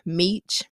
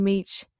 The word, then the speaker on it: Meech: One of my nicknames is Meech. And a lot of things rhyme with Meech.